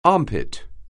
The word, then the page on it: armpit: anglais